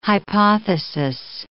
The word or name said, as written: hypothesis